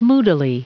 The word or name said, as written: moodily